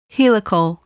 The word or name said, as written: helical